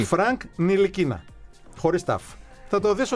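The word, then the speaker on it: Ntilikina: Ντιλικινά. – Όχι. – Εσύ, μισό λεπτό. – Όχι. – Συμφωνούμε ότι είναι ντι. – Όχι. – Όχι. – Νι. Το τάφ δεν προφέρεται. Στα γαλλικά.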